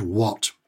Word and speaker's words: what: The question this lady had was, take the word what. Why is it pronounced what? When you take the letters H-A-T on their own, they're pronounced hat. Put a W in front of it and suddenly it becomes what? – That's a really good question.